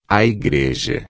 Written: igreja

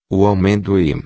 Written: amendoim

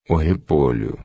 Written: repolho

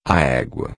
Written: egua

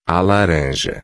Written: laranja

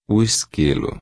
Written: esquilo

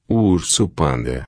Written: urso-panda

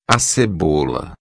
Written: cebola

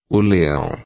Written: leao

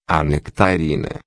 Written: nectarina

